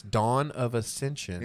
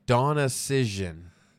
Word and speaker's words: Dawn of Ascension: She mispronounced our name. – Nice. – And what'd she say? – So, it's Dawn of Ascension. – Yeah, no. – She said... Donna Cision. Donna Cision.